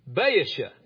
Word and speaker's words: Baasha: It says, in the sixth and 30th year of the reign of Asa, Baasha is how you say that. I looked it up. I found a thing that reads it to you on the internet. So I know exactly how things are pronounced now.